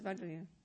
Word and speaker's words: Vajra: He says Vajra as well, not Benza anymore. Uh-huh. Interesting. Yeah, he says Vajra.